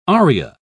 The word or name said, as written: aria